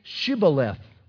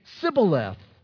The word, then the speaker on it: Shibboleth: Then they would say to him, then say Shibboleth. And the guy would say Sibboleth, for he could not pronounce it right.